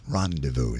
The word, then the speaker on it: rendezvous: And he said, that's rendezvous. It's French. It's not pronounced the way it's spelled.